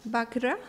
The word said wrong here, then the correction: Vajra: so the pronunciation is not easy for me, the thunderbolt, the weapon of the Indra's weapon, the thunderbolt is Vajra. Vajra.